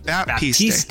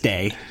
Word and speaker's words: Baptiste: How do you say his name? But Baptiste. Baptiste. God.